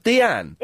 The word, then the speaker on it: Dian: D-I-A-N. – And it's pronounced Deanne.